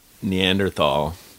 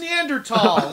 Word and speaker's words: Neanderthal: Neanderthal, excuse me. – Excuse me. I apologize. Imagine that's the thing that people write us on Twitter. It's pronounced Neanderthal.